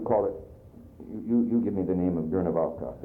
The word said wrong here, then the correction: Guernica: because I've said it wrong all along. – Guernica, that Guernica painting.